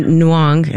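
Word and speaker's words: Nyong'o: They had a picture of Diane Kruger next to a picture of Lupita and Nuong. Is that how you pronounce her last name? – Yes.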